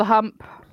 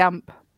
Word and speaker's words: hump: I've got the hump. Or if you're going to say it properly, you drop the H. I got the ump.